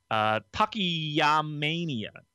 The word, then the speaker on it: Takayamania: who is heading up the I always mispronounce it because it's a real tongue twister, but it's Takayamania.